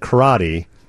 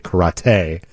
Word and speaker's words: karate: Because forever people said karate, and then all of a sudden you had to pronounce it correctly, karate.